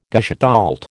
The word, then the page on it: gestalt: American English